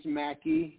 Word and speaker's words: Mackie: Yeah, they can go to Mans Mackie, M-A-C-K-I-E, so that's Mans, M-A-N-N-S, no apostrophe, my last name is spelled with an S, Mans.